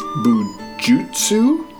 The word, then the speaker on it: bujutsu: Born into a respected family with a long bujutsu? I'm sorry. Because I'm sure that was butchered. – That's probably the word beginning. – Yeah. It's B-U-J-U-T-S-U. – Bujutsu.